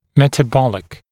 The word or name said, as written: metabolic